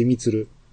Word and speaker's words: 326: 私も忘れました。あのー、 326 と書いてみつる。